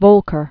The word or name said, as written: Volcker